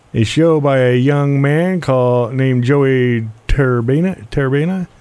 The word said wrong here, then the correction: Tariba: I think it's pronounced Tariba. – Tariba, there you go. Thank you. You're right. Joey Tariba out of Bernie.